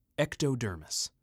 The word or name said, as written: Ectodermis